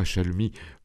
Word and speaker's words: chalemie: La chalemie ou chalemie, je ne sais pas comment il faut prononcer. – On dira chalemie.